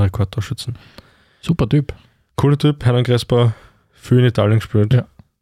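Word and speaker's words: Hernán Crespo: Jetzt muss ich aufpassen, dass ich es richtig ausspreche. Hermann Crespo? Ja, wir sagen da Sehr cool. – Hermann, Hernan Crespo, ja, ja.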